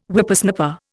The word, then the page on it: Whippersnapper: Indian